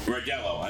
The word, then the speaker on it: Rodela: Rodela.